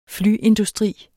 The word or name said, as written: flyindustri